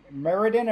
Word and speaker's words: Meriden: in Meriden, am I pronouncing that right? – Yep.